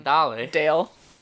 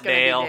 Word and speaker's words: Dale: I was listening to Pitbull's new album, Dale. Today, well, it's actually Pitbull's predominantly English language albums, it might very well be pronounced Dale. – Mostly Spanish. – But it's one of his mostly Spanish albums. – When he releases the North American version, then you call it Dale.